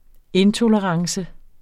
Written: intolerance

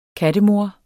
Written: kattemor

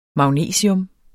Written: magnesium

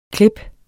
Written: klip

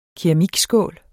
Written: keramikskål

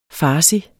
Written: farsi

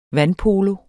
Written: vandpolo

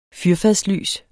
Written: fyrfadslys

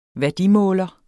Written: værdimåler

